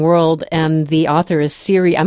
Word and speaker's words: Siri: But in case you're on the edge of your seat right now, Siri is just like Siri with an iPhone, S-I-R-I, and her last name is spelled H-U-S-T-V-E-D-T.